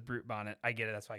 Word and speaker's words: Brute Bonnet: I'm out on Blue Bonnet, or what's his name? can you spell it? Can you spell it for me? – Brute. Space Bonnet. – Brute Bonnet.